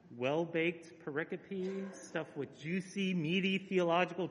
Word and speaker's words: pericope: One, a set of verses that form a single unit or thought is called a pericope. And if you pronounce it pericope, your pastor will laugh at you.